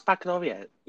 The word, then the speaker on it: Baccalaureate: We did you say Welsh Bachelorette? That's not how you say it. – I said Welsh Baccalaureate.